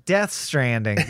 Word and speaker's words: Death Stranding: That's right, Death Stranding, because every character pronounces it with that emphasis, and I want to do honor to this fantastic game pronouncing it correctly.